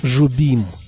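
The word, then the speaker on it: Jobim: 25 января 1927 года родился и 8 декабря 1994 года ушел из жизни крупнейший автор бразильской современной музыки Антонио Карлос Жобим. Да-да, это имя в Бразилии произносится именно так.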